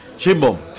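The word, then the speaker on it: Sheinbaum: Eh, Claudia, ¿cómo se pronuncia, doctor? Shimbom. Shimbom, sí.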